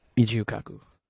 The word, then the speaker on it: Michio Kaku: Michio Kaku.